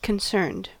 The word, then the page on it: concerned: American English